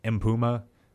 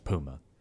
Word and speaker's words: Mpouma: His last name is spelled M-P-O-U-M-A. – Oh, so it still works. – It looks like M-Puma, but it's just pronounced Puma.